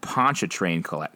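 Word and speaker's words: Pontchartrain: Now he just needs to pronounce Ponchatrain correctly. But that's easy. Ponch-a-train. Don't pronounce the A or R and pronounce it like eh.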